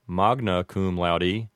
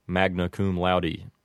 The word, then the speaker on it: magna cum laude: And then you would have magna cum laude as a correct pronunciation, but with an Americanized pronunciation, it might sound like magna cum laude.